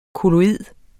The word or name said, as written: kolloid